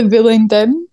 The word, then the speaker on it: Bullingdon: At the Bullingdon. Is that how you say it? – Yeah.